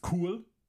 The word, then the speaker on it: cool: You should say, das ist cool. – Das ist cool. – Cool. – Cool. – You got to not pronounce it in the English way.